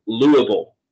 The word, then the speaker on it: Louisville: It's Louisville.